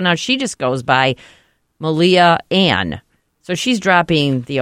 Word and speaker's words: Malia: So anyway, she's going by Shy now, and another famous person, one of the Obama girls, Malia, that's how you say her name, right? Malia. Yeah.